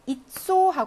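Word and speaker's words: 잇소 (яйцо): 달걀 잇소 하고 강세가 야에 없답니다. 그래서 야가 이 발음 되겠습니다.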